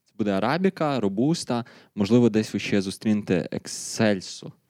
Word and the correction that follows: Ексцельса: Пан, чи я правильно вимовив. Ексцельса? Ексцельса. Ексцельса.